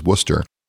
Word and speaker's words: Worcester: Little things like how to pronounce Worcester properly, which is Worcester, and the absolute rabid devotion of the Red Sox fans toward their team.